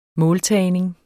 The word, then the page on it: måltagning: -ˌtæˀjneŋ; -ˌtæːjneŋ